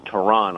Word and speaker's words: Tyronn: Well, actually, it's pronounced Tyronn, although everyone calls him Ty, because it's T-Y-R-O-N-N.